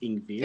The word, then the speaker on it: Ingvild: So you don't pronounce the D, Ingvild.